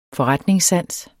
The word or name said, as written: FORRETNINGSSANS